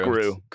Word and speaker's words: Gru: I thought it was Guru. How you say his name? – No, it's just Gru. Just Gru.